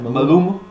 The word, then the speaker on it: Maluma: It's Fame by Maluma. I'm saying that right? – Maluma.